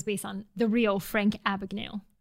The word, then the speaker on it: Abagnale: Is that how you say it? Abagnale? – Frank Abagnale.